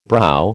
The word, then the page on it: brow: American English